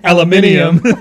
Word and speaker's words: aluminium: This is an aluminum. – Aluminium.